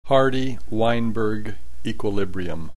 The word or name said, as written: Hardy Weinberg equilibrium